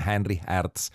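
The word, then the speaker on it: Henri Herz: Aquesta era la variació número 4, la variació de Henry Hertz, o si ho voleu pronunciar en alemany seria alguna cosa així com Henry Hertz.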